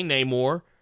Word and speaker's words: Namor: I think Marvel is keenly, and I think I've even heard Stan Lee pronounce it this way too. It just is what it is. I think they just recognize more people commonly say Namor, and other people say it another way.